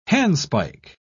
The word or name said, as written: handspike